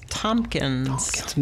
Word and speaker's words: Tomkins: My last name is Tompkins. – No P. I keep saying Tompkins, and I'm sorry. – I know. Everybody puts a P in there.